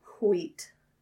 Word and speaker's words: wheat: That's how you pronounce wheat. The H in there.